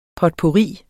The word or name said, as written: potpourri